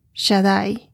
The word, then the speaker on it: Shaddai: El de Dios, Shaddai, S-H-A-D-D-A-I, así se pronuncia.